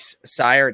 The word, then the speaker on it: Cyr: Blake Sear. It's spelled C-Y-R. Yeah, I'm sorry if I pronounced that wrong. Sear, thank you.